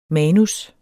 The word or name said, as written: MANUSSENES